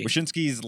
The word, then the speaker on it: Wyshynski: I feel like has to be mentioned because a lot of people saw Greg Wyshynski, I think his name is pronounced. – Wyshynski. – Wyshynski's list of players to drop, and Jamie Benn was on it for some reason.